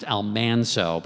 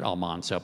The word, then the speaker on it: Almanzo: I mean, she and Almanzo, and I've been told, by the way, that his name was actually pronounced Almanzo, but in the TV show they made it Almanzo.